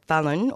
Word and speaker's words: Tephalin: So my guest today is Tephalin Ou, or Fallon, or Paulin, depending on your pronunciation. Her pronunciation is Fallon.